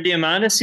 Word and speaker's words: Diamandis: Peter Diamandis.